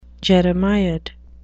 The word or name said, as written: jeremiad